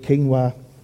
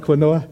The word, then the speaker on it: quinoa: and eat quinoa. That was a joke. I'm just showing off because I know how to say it now. I used to call it quinoa.